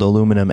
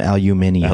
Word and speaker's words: aluminium: He was English, and he called aluminum aluminium. – Aluminium. Oh, yeah. But it's not spelled like that. – But he nevertheless pronounced it like that.